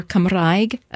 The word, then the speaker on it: Cymraeg: And Welsh, or Cymraeg, as it's pronounced, as it's said in Welsh, is on the uptick, and people have devoted themselves to that.